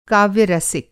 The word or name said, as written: काव्य-रसिक